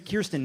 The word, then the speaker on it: Kirstjen: Then, over the weekend, Secretary of Homeland Security Kirstjen Nielsen, the J is silent because it's gay and terrified of being sent back to its home country.